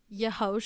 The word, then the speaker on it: yeho: Yeah, it's pronounced, don't say it like yeehaw, it's yeho.